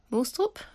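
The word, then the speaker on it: Moestrup: Also Mette Mostrup, how do you pronounce the name again? – Mostrup, I think you can pronounce it better than me, so leave it your pronunciation.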